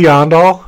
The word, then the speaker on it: Aandahl: Yeah, name's Casey Aandahl.